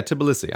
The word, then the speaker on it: Tbilisi: Tbilisi. Is that how you pronounce it? Capital of Georgia. – Oh, yeah, Tbilisi.